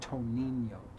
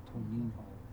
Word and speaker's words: Toninho: It's spelled T-O-N-I-N-H-O. Like it looks like Toninho. Toninho. It's pronounced Toninho or Toninho. They don't even pronounce the other N. His last name is spelled H-O-R-T-A.